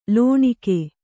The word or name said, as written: ಲೋಣಿಕೆ